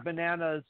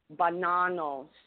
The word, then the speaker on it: Bonanno: Yeah, we've just Joseph Joey Bananas Bonanno. – Bonanno, say it right, Bonanno. It's not banana, Bonanno.